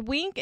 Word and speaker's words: Winc: So it's Wink. It's spelled W-I-N-C.